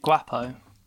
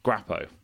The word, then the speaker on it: Guapo: So this is the, hope I pronounce this right, El Gapo? – Yes, it means handsome. – Oh, thank you. – Is it Guapo? – Guapo? Yeah. Guapo.